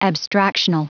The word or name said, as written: abstractional